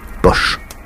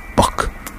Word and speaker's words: Bosch: Car Jérôme Bosch peut se prononcer Bosch ou Bock.